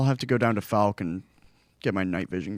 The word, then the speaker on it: Fouke: Spelled F-O-U-K-E, but you pronounce it Fouke.